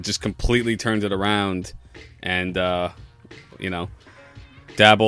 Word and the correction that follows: Dable: Or am I pronouncing his last name right? I feel like I always fuck it up. – Dable. – Like table, but Dable.